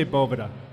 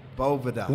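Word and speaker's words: Boveda: So can you please educate us, boneheads? Because all three of us have said different names. – We say Bovida. – Bovida. – We say Bovida. And as you say Bovida, B-O-V-I-D-A.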